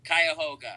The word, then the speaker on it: Cuyahoga: And then Thursday night is the Rubber City Comedy Festival at the Funny Stop in Cuyahoga Falls. Am I saying that right? – Cuyahoga. Yeah.